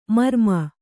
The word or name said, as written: ಮರುಮೈ